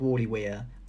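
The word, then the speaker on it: Warleigh Weir: And I think Worley Weir, I don't think you're crazy, Will.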